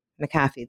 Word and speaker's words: McAfee: Oh, I'm going to say it wrong. Jeez, and I interviewed her. McAfee. There we go.